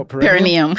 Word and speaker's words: perineum: Let's talk about massaging perineals. Is that how you say it? – No, perineum. – Perineum.